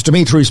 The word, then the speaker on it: Dimitri: Go to the Big Willie 105.5 Facebook page or my profile page, Dimitri spelled with three I's, D-I-M-I-T-R-I.